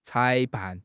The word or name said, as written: 裁判